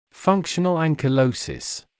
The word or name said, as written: functional ankylosis